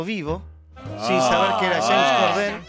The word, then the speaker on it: James Corden: Este chico, James Corden, anda a pronunciarlo en inglés, James Corden. – Corden. – Se sacan como todo lo que tiene el apellido y te quedas con la N y algo más.